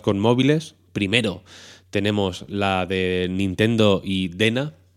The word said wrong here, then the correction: DeNA: DNA, perdón, pronunciemos con propiedad,